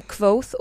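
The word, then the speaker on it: Kvothe: Den, eh, den, alltså den handlar ju om Quoth och ja, det är så det uttalas, jag har mm.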